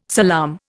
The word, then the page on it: salaam: Indian